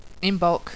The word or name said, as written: Imbolc